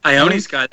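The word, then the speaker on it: Ione Skye: I don't know how you pronounce it. Is it Ione Sky? – Ione Sky, that's right.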